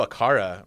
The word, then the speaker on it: Aqara: I know Aqara, right? Is that how you pronounce it? A-Q-A-R-A? – Yes.